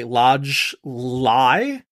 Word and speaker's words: Ladj Ly: But this is a contemporary sort of crime thriller directed by Lodge Lee, thank you.